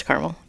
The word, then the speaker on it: Carmel: Dang it, did I say that wrong? – No, it's Carmel.